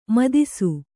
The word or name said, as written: ಮದಿಸು